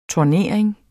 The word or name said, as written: TURNERINGERNES